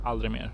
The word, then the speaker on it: Aldrig mer: Aldrig Mer, or Aldrig Mer in actual Swedish.